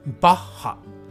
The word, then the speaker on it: バッハ: もうね日本人はこうやって発音しますバッハ。